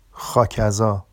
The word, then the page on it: xɑcazɑ: Vafsi